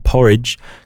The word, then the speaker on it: Porij: This is from a band called Porridge. It's spelled P-O-R-I-J.